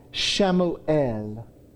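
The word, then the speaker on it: Shamuel (שמואל): Samuel's name in the Hebrew is pronounced Shamuel. So we say Samuel, Shamuel.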